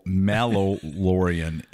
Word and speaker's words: Mallorean: So the first five were called the Belgariad, and then the second five were the Mandalorian. And the Mandalorian? – The Mallorian, Mallorean. Okay. I just butchered that. Take the Mandalorian without the duh in it.